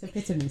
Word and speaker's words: epitome: It's epitome. It's okay. – She's epitome. Oh my God, I actually tweeted that today. I've tweeted epitome. – No, that's how you spell it. It's not how you say it.